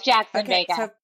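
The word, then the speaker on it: Jaxon Vega: Is it Jackson Vega, or do I pronounce it differently? – It's Jackson Vega.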